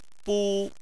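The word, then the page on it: bu: tone 1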